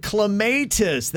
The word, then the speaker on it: clematis: It is supposed to be called clematis. That is actually how you pronounce it.